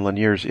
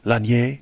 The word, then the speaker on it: Lanier: However one would like to pronounce his name, yes. Apparently he has a little preference. – Lanier. – Lanier. Yeah, he's probably fine with that. – Called What Went Wrong with the Internet.